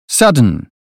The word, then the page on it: sudden: British English